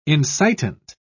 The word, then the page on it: incitant: British English